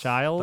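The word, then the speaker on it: Chiles: I think it's Childs. – Childs? – That's what I said. – Lois Childs. – It looks like Chili's. – Chile's. – So Lois Childs, what do you think for her?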